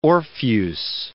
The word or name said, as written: Orpheus